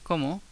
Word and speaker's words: cómo: And it's spelled like I eat, como, but with an accent on the first o. Como.